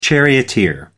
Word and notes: charioteer: American English

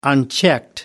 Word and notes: unchecked: American English

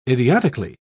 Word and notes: idiotically: British English

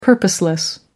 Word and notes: purposeless: American English

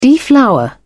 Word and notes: deflower: British English

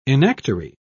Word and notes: enactory: British English

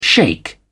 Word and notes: shake: British English

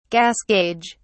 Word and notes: gas gauge: British English

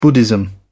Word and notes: buddhism: British English

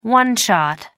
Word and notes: one-shot: British English

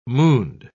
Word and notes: mooned: British English

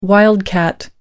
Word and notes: wildcat: American English

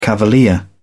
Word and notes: cavalier: British English

